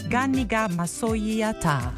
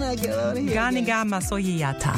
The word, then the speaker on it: Ganiga Masoyiata: That was the song of the week, Ganiga Masoyiata. – Oh, you said it right. – Did I? – You can speak Hausa. You can learn Hausa language so fast. Oh. Say it again. – Ganiga Masoyiata.